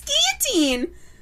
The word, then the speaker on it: guillotine: Is it guillotine? – It's guillotine. – Oh, that's not how my teacher said it.